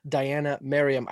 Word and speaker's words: Diania Merriam: Welcome to the conversation I'm having with Diana Merriam. I hope I'm pronouncing that name right. – That is correct.